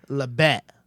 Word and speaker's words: La Bête: La Bête. – La Bête. – La Bête. – La Bête. – You gotta say it like you're throwing up.